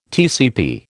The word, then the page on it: TCP: American English